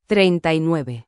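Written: treinta y nueve

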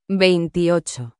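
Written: veintiocho

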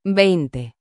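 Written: veinte

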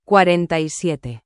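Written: cuarenta y siete

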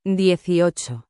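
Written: dieciocho

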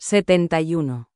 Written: 71

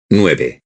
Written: nueve

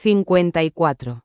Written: 54

